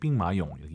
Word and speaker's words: 兵马俑: 병마용, 병마용 하지 마시고 이제 삥마용 예쁘게 발음하시기 바랍니다.